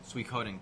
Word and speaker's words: Suikoden: Is that how it's pronounced? – Suikoden? – Suikoden.